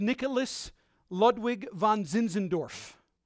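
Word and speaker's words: Nikolaus Ludwig von Zinzendorf: Count Nicholas Ludwig von Zinzendorf.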